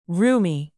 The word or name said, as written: roomy